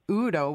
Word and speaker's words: eu: well, the Greek word translated prosper or prospereth in the text is Udo, which is comprised of the words hodos, which means road, and EU is pronounced U, which means good.